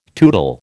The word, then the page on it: tootle: American English